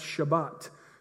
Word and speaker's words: Shabbat: In fact, on the Sabbath over there, you'll hear it all the time when you're over there, the word Sabbath in Hebrew is pronounced Shabbat.